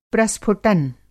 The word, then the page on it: प्रस्फुटन: Hindi